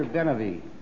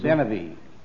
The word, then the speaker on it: Benavie: Arthur Benavie. 20-year-old violinist. – Oh, grand. Now, give me his last name again, please. – Benavie. – Benavie. How do you spell it? – B-E-N-A-V-I-E.